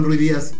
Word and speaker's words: Ruidíaz: Porque ¿y Ruidíaz, cómo se pronuncia? – Raúl Ruidíaz, así. Porque es peruano.